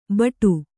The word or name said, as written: ಭಟ